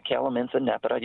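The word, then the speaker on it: Calamintha nepeta: Yeah, well, I think it's important to mention that the calamint, showy calamint, Calamintha nepata, you were close, you were right on, is not a native plant.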